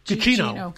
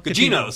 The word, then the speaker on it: Gugino: And Carla Gugino, am I saying her name right? – Carla Gugino. – Gugino. Sorry, yes, Carla.